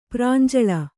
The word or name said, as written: ಪ್ರಾಂಜಳ